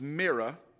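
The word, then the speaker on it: Merab: Her name is Merah. Now, in our English Bible, there's a B on it, but there is no B pronunciation in the Hebrew, and so it's Merah.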